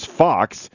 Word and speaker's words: Fuchs: You know, it's funny, too, like Tommy Fox, so there's a lot of people in my hometown, they call it's F-U-C-H-S, so it's a lot of people in my area would call it Fuchs, but in the Glendon area, it's the German pronunciation, which is Fox, which doesn't look like F-O-X at all, but so there's a lot of that.